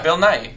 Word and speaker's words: Nighy: Yeah, Bill Nighy.